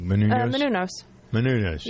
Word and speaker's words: Menounos: So I'm wondering, as I'm looking at Maria Menounos, is that how you pronounce her name? Menounos, I think. – Menounos. Yeah.